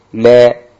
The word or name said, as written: re